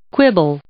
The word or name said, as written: quibble